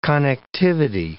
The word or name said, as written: connectivity